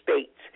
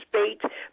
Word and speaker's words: Spates: You may find me on Facebook under Minister Patricia Spates. Minister Patricia Spates. My last name is spelled S as in Sam, P as in Paul, A as in Apple, T as in Tom, E as in Evelyn, S as in Sam.